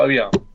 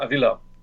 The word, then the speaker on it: Avila: Ja, Steve Avila. Nicht, jetzt habe ich falsch ausgesprochen. Avila. So.